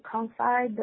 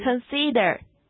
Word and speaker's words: consider: So the next time you are getting ready to make a call, Stop and confide the people around you. – Consider.